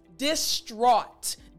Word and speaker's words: distraught: Now I want to explain how to pronounce this word properly. So distraught, distraught. Now you'll see in this word, of course, you know how to pronounce the D sound properly. But when you look at the word, you see this A-U-G-H, A-U-G-H. And when I say the word distraught, you hear it at the end, right? Aw. So when you have A-U-G-H-T together, the sound is actually similar to A-W-E.